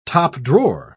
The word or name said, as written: top-drawer